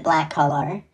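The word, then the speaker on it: Blackolor: It's B-L-A-C-K-O-L-O-R. I'm pretty sure he pronounces it Black Collar.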